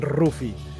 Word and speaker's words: Luffy: És Rufi, Rufi, Luffy, en sí, japonès, sí. i sona com una R i va deixar Rufi. Passa que diem massa, pronunciem massa la R nosaltres, com yeah. ratolí, i acabem dient Rufi.